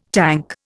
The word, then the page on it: tank: Indian